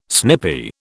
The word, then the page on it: snippy: British English